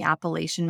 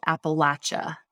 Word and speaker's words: Appalachia: in the Appalachian Mountains that's been living under a curse for generations.